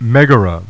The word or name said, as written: Megara